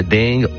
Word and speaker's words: Deng: Plusieurs prononciations, hein, ailleurs en Alsace, le Ding, le Deng.